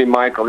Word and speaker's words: Michael: Everybody calls me Michael. It's a Dutch name, officially Michiel, but nobody can say it out of Holland.